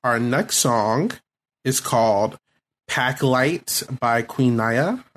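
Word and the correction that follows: Naija: I believe that's how you say her name. – Queen Naja. – Naja. Okay. Naja.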